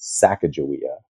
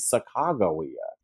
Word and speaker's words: Sacagawea: We might know her name as Sacagawea. – I've been saying it wrong the whole time. – Well, so it's interesting. We all learned her name is Sacagawea.